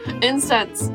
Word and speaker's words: incense: No, I can't say this word. Incest. Incense. Oh my gosh. – It's a great word to get stuck on. – I don't even know what being incensed. Incest. See, now I said it correctly.